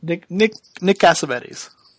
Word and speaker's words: Cassavetes: Nick Cassavetes.